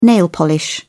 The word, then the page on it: nail polish: British English